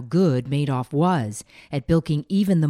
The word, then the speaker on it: Madoff: But Madoff, yes, pronounced Madoff, wasn't just successful.